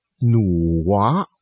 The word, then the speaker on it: 女娲: 老实告诉您，女娲这两个字不念成女娲，您去查任何的词典，它都会告诉你这两个字念努娃。而且在上古的传说里边，这个努娃并不是女的，这两个字啊只是拿来做她名字的音译而已。